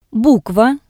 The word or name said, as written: Bukva